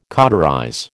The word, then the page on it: cauterize: American English